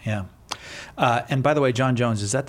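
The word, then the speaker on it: J'onn J'onzz: But it's pronounced John Jones.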